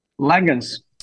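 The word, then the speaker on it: Langhans: Is it Langens? Is that how you pronounce his last name? – Langens. Yep, correct. Langens, yeah.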